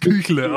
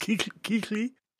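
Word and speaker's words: Kuechly: Ja, ebenso wird er nicht ausgesprochen, aber es sieht genau. so aus wie es Küchle, aber es ist kein Küchle, sondern es ist Kikli.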